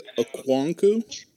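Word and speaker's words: Okonkwo: I know him as Chig Okwonkwo.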